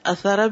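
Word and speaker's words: أثرة: اب یہ لفظ جو ہے اثرہ بھی ہے اثرہ بھی ہے, ٹھیک ہے, تلفظ جو ہے دونوں طرح. تو پچھلی احادیث میں آپ نے اثرہ پڑھا ہے, الف پہ پیش اور تھا پہ جزم, اور یہاں کیا ہے اثر اثر، تینوں متحرک یعنی تینوں پہ حرکات ہیں.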